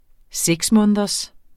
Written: 6-måneders

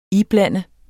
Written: IBLANDEDES

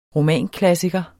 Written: romanklassiker

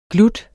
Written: glut

